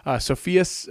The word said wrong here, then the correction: Sophia: No, Sophia the third. So maybe it's Sophia. Sorry, Sophia. Blech.